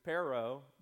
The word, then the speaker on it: perro: And I didn't learn much from Spanish, but one word I remember was the word for dog, perro, and you have to roll your R's because perro by itself is the word but,